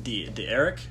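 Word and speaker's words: D'Eriq: His Heisman, he had Derrick King. – I think it's pronounced D. Eric.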